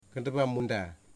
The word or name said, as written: Kendabamunda